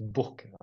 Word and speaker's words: Bourg: D'ailleurs, je ne sais pas si tu as dit, je sais pas si tu as dit, en fait, même si c'est Bourg-en-Bresse, en fait, les, ça se prononce Bourg, apparemment, donc c'est important.